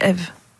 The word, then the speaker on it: EV: Elle est avec nous cette semaine, Florane est notre invitée, elle tient le restaurant Eve, on rappelle que ce restaurant EV. Hein. – Eve, ouais. – Ça s'écrit EV mais c'est Eve.